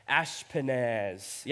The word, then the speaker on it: Ashpenaz: So, verse 3, it goes, then the king commanded, how do you think you say his name? Ashpenaz. Yeah, say it with me.